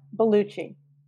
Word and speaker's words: Bellucci: His name is Fabrizio Fab Bellucci.